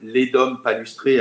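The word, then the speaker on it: Ledum palustre: Il y a un dernier médicament qui est l'EDUM palustré, L-E-D-U-M palustré, comme ça se prononce, qu'on peut prendre en 15 CH.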